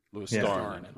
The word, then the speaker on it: Luostarinen: Luostarinen. Luostarinen. – Yep. – Star is the most powerful syllable. – Luostarinen. Yeah. Okay, there you go. – Nailed it. – The emphasis is on star.